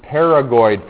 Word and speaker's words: pterygoid: We call this the pterygoid process. We don't pronounce the P.